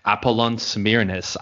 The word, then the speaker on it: Apollon Smyrnis: Apollon Smyrnis, I think, is how you pronounce the name of the club. – Yep, that's correct.